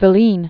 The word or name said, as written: Filene